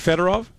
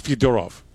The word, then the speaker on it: Fedorov: I think that as long as we know who we're talking about, like, look, Russians will say all the time, like, Sergei Fedorov, his name in Russian is Fyodorov, right? And we're merging, we're evolving towards the Russian pronunciation.